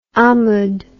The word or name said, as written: amud